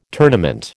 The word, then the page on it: tournament: American English